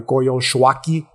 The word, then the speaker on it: Coyolxauhqui: It's hard to pronounce. It's, it's spelled C O Y O L X A U H Q U I. So for those of you who are interested, okay, the Coyolxauhqui consciousness, right?